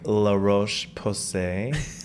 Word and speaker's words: La Roche-Posay: And I also really like La Roche-Posay. – I don't know why he says it like that. – That is how you say it.